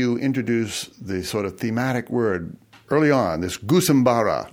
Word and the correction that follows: Gusimbura: Gusimbura. It's actually Gusimbura.